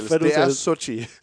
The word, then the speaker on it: Xochitl: Det er, S-O-H-C-H-E-E. – -E. Sådan skal det udtales. Så det må være Sochi. – Ja. Sochi Gomez. Der er et L midt i det hele også, der jo. Jamen, det er der, men det må være stumt. Det er L. Ja, det staves X-O-C-H-I-T-L.